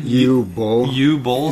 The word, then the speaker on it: Uwe Boll: Uwe Boll.